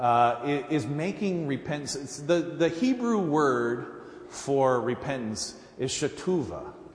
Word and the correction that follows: Teshuvah: And no, I said that wrong. Teshuvah. I did that backwards. Teshuvah, which literally means to return.